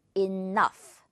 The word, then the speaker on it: enough: GH가 있지만 F 발음한다는 거. 이 단어를 대표로 기억해도 좋아요. Enough. 자, 예문 볼까요? 그거면 충분하지 않아? Isn't that enough? 라고 해볼까요? Isn't that enough? 그 정도면 충분하지 않니? 이너그흐 아니겠죠? Enough. F로 처리해주면 아주 간단하게 끝!